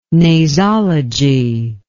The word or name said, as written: nasology